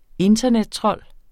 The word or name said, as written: internettrold